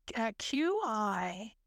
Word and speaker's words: Qigong: That's Qigong.